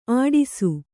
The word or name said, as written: ಅಟ್ಟಿಸು